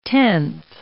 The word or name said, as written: tenth